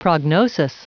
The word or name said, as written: prognosis